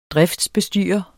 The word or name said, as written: driftsbestyrer